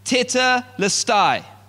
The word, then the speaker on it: tetelestai: This word, "it is finished," in the Greek, is a word that is pronounced tetelestai.